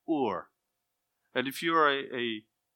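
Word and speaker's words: Ur (אור): and you pronounce that Ur.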